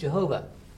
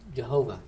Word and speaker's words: Jehovah: So we English folks came along and went, oh, it's pronounced Jehovah. And we went ahead and used their misplaced vowel points to get Jehovah.